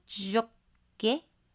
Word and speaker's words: Jacquet: Do you pronounce the T? No. – I don't. – Yes? No. – I mean, I don't ever say it. – Luke Jokit. No.